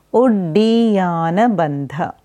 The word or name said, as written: Uddiyana Bandha